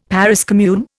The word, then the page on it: Paris commune: Indian